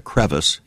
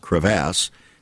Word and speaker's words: crevasse: And discouragement can drop us into a deep, dark crevasse in our lives so you might pronounce that a crevice. It's pronounced crevasse that seems impossible to climb out of.